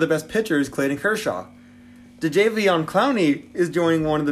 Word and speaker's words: Jadeveon: Jadeveon. J-A-D-E-V-E-O-N.